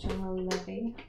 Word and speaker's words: General Levy: I like how you said General Levy as if you weren't sure exactly how it's pronounced. – I'm not. – But you did well.